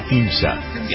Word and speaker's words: Ahimsa: Victoria Ahimsa.